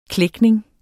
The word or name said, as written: klækning